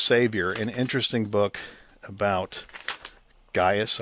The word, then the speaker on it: Gaius: And of all the words that you stumbled over, you pronounced Gaius perfectly.